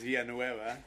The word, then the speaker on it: Villanueva: It's like Villanueva or something like that. – It's Villanueva. – How say it again? – Villanueva.